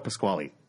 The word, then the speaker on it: Pasquale: how do you say Pasquale or Pasquale? – Pasquale.